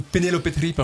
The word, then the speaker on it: Penelope Trip: Et donc Penelope Trip, je le prononce comme il le prononce en Espagne, c'était vraiment un groupe marquant en Espagne, donc, au début des années 90.